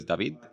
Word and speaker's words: David: I'm originally from Spain, so if you want to pronounce my name the way I would pronounce it, is David.